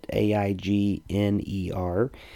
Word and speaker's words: Aigner: Um, it's called some uh, what Mr. Rogers can teach us about worship, and it's written by Jonathan Aigner, I believe is how you say his name. I'll let you look it up, but A I G N E R,